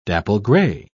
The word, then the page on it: dapple-grey: British English